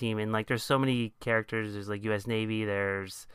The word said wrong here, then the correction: Usnavi: It's pronounced Usnavi, and that's that's a real Cuban thing.